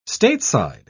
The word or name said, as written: stateside